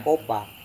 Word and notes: kopa: serewen

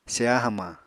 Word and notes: seya hamañ: Apatani

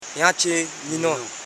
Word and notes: jachinyi: ja.chi.nyi

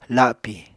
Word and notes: lapi: apatani

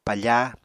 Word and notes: pafa: Apatani

